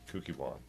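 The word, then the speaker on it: Kukkiwon: This is to certify that the above-named person is a holder of the Taekwondo 3rd Dan grade registered in the Kuki Wan.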